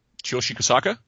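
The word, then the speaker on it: Tsuyoshi Kohsaka: He is fighting, how do I pronounce his name? I always have trouble pronouncing his name. The fellow that beat, quote-unquote, Fedor with the cut. Kasaka? – Chiyoshi Kasaka? – There you go.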